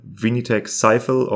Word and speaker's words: Vinitech Sifel: It is spelled V-I-N-I-T-E-C-H-S-I-F-E-L. Vinitech Sifel, or how you pronounce it in Francais, Vinitech Sifel.